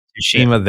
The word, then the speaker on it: Tsushima: Like I don't know if you guys played Ghost of uh, Tsushima or Tsushima. I don't know how to pronounce Tsushima. The, the yeah, yeah. – Tsushima. Thank you.